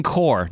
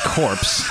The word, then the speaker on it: Corps: The Marine Corps, not the corpse.